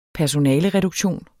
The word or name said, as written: personalereduktion